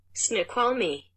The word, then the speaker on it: Snoqualmie: It looks like it's pronounced Snoqualmie. – Okay. – But this is what Google Translate thinks it sounds like. – Snoqualmie. – That's kind of what you said, yeah.